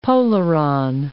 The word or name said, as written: polaron